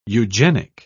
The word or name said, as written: eugenic